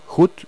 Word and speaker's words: Hut: Bueno, esto disgustó mucho al intendente de Aristóbulo del Valle, el intendente Hut, creo que así se pronuncia, H-U-T es el apellido, y bueno, ya...